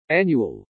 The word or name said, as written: annual